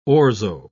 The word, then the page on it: orzo: British English